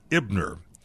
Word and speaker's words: Eibner: Now, the Dodgers also acquired Brett Ebner. I believe that's the way you say his name, E-I-B-N-E-R.